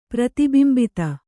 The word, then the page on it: ಪ್ರತಿಬಿಂಬಿತ: prati bimbita